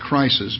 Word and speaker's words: krisis: It comes from the word K-R-I-S-I-S. It looks like crisis, but I don't know that's correct pronunciation of it. Zach says that's correct.